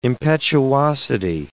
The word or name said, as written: impetuosity